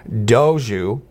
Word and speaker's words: Dogue: Doju, D-O-G-U-E, which rhymes with Doja, which rhymes with Vogue, opened last month in the city's trendy Mission District.